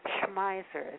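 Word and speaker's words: Schmeiser: His name is Percy Schmeisser. It's S-C-H-M-E-I-S-E-R.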